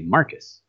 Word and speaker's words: Marcos: It's one of those names that's it's spelled B-E-X-A-R, but it's Bexar. It's like they have a city called San Marcos, but it's spelled M-A-R-C-O-S, but they say Marcus. Weird.